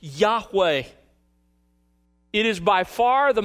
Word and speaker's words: Yahweh: But we believe the best pronunciation is something like Yahweh. Yahweh. It's anglicized. You may have seen it anglicized as Jehovah when you were growing up in older Bibles. But it's Yahweh. Now, in most English translations, it's translated by the word Lord in all caps.